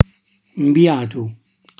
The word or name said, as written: mbiatu